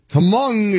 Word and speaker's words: Hmong: Hmong is, it's not Hmong, but it's spelled Hmong.